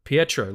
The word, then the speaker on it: Pietro: And it was edited by Pietro Scalia, I think is how you pronounce it. Pietro, like Colossus.